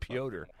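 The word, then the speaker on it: Pyotr: Let's go with Piotr. Piotr Tchaikovsky, his ballet, The Nutcracker, premiered in St. Petersburg, Russia.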